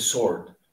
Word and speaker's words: Sword: No sé cómo se, ¿Cómo se pronuncia Sword, eh? – Eh, con el acento de acá no se pronuncia la W, entonces Sword.